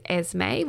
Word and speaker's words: Esmi: So I have an exciting offer to share with you with Esme, which is spelled E-S-M-I.